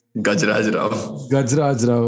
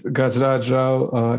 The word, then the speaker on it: Gajraj Rao: Gajraj Rao, okay. Uh-huh. So it's Gajraj Rao, Nina Gupta, Ayushmann Khurrana, Surekha Sikri, who I think is probably exceptional in this movie.